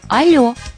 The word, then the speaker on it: алло: Отвечаю «Алло». Обратите внимание, строгая норма предписывает именно так произносить эту реплику. «Алло».